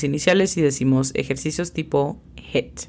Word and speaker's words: HIIT: La pronunciación fonética es simplemente J-E-T. Atención, porque puede hacer una gran diferencia el cómo pronunciamos la E. La E en esta palabra es sumamente corta, es HIIT, no es HIIT, es HIIT.